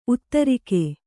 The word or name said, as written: ಉತ್ತರಿಗೆ